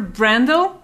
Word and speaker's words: Brandel: Uh, with us, Jennifer Brandel, founder and CEO of Hearken.